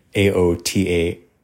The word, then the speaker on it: Aotearoa: And I believe I'm pronouncing that correctly. Aotearoa.